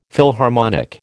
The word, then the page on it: Philharmonic: American English